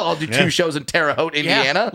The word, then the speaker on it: Terre Haute: I don't even think I'm pronouncing that correctly. It's probably Haute. – It's Terre Haute.